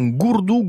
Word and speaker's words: Gordon: Eh oui, Gourdon, qui vient de l'occitan Gourdou, Gourdou, que vous écrivez, et lingo nostro, G-O-R-D-O-N. En occitan, la lettre O se prononce OU, d'où la prononciation Gourdou.